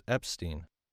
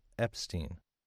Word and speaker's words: Epstein: Sure, it's Epstein. Mike Epstein. – Could you please spell that? – Certainly. It's Epstein. E-P-S-T-E-I-N.